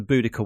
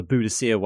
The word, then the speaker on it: Boudica: Because because the the from between Diss and Norwich is the Boudica way, or the Boadicea way, however you want to pronounce it, but it's, it's spelt as Boudica around here.